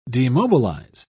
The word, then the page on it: demobilise: British English